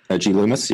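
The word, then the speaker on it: G. Loomis: That's how you say it? – G Loomis? Yeah. – It's Gloomis. I'm like, man, what a dreary name for a company. Like, who wants to fish with a Gloomis? – No, totally.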